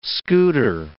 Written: scooter

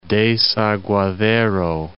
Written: Desaguadero

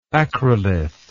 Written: acrolith

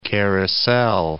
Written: carousel

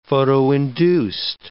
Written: photoinduced

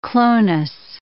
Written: clonus

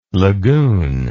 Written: lagune

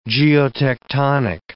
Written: geotectonic

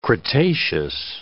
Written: cretaceous

